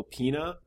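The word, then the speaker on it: Peña: I also wanted to say Michael Pena, I think is how you pronounce his last name. – Yeah.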